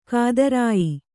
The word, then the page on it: ಗೋತ್ರಯ: Kannada